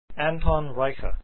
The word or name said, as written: reicha